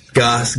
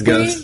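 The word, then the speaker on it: Gose: So, my name's kind of funny as well. It's G-O-S-E. Goss, Goose, Jose. – Yeah, whatever. – It's actually pronounced Ghost.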